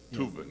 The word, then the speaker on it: Thuban: Oh, I mistook it for the Zubin, so it's Thuban.